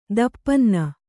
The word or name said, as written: ತುಪ್ಪನ್ನ